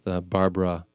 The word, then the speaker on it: Barbara: in an interview with Barbara Walters? – Uh-huh. – Yeah. How do you say her name? – Baba. – Baba Walters. – Barbara.